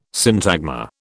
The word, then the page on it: syntagma: British English